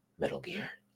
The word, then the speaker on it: Metal Gear: Metal Gear. No, say it like Richard Gere.